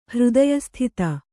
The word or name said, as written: ಹೃದಯಸ್ಥಿತ